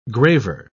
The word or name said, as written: graver